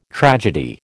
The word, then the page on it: tragedy: American English